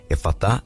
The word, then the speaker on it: Efatá: Efatá se escreve do jeito que se fala: E-F-A-T-A.